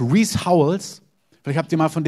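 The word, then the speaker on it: Rees Howells: Reese Howells, schreibt man R-E-E-S und dann Hovels, also Reese Howells, hat ein Buch über Fürbitte geschrieben.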